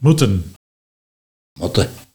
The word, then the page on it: Mottuh: Nieuw lekkerlands